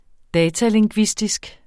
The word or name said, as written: datalingvistisk